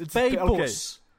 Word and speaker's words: Peipus: Peipsi. did I pronounce that correctly? – Peipsi. Really, Glenn? Really? It's Peipus. Okay.